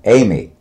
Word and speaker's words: Aime: My full name, A-I-M-E, Amy Foisy. F-O-I-S-Y, Foisy.